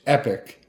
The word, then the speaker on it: Epoch: I'm sure you say Epic, but it's E-P-O-C-H. – Epic.